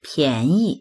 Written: pian2yi

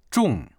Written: zhong4

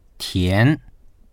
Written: tian2

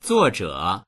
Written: zuo4zhe3

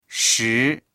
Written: shi2

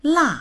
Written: la4

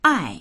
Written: ai4

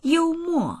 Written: you1mo4

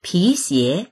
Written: pi2xie2

